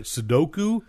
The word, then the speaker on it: Sudoku: Sudoku? – Yes.